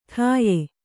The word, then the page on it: ಠಾಯ: ṭhāya